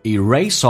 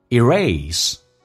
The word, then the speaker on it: erase: It will erase all the bad memories you had yesterday. Erase, 这个单词英式发音叫 erase, 美式发音可以发成 erase, 都对 alright?